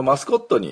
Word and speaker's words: mascot: で、最初のあのところが、お、みゃーの、ね、お、みゃー、何やっとんだな、あとえの間の、発音記号もあと絵がくっついたようなやつですね。でこれでマスコットマスコットっていう風に発音します。